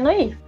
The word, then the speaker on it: noir: É, Noir a pronúncia certa? Noir? Noir? Noir? – Bem, no português seria Noir.